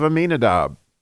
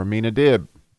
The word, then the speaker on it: Amminadib: my soul made me like the chariots of Amminadab," or Amminadib, depending on how you want to pronounce it.